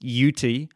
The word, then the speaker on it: Jutes: Their name, which is more properly pronounced Yuti, is nearly identical to the Hebrew word for Judahite, Yudi.